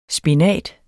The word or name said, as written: spinat